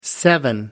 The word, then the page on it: seven: American English